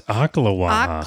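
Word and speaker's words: Ocklawaha: Oklawaha, because they either spell it O-C-K or OK. Some people just do OK. Okay. I was spelling it O-A-K like oak, but I looked it up and it's Oklawaha.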